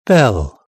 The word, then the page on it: bell: British English